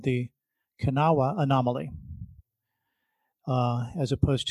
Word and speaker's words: Kanawha: I named it what I thought the Kanawha Anomaly, named after a region in West Virginia. And I was bragging about it on Bagel Bards with some folks. And one of the guys said, I hate to tell you this, but it's pronounced Kanawha. It's a Native American name.